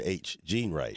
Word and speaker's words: H. Gene Wright: It's actually Dr. H. Gene Wright.